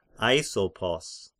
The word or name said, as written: Aisopos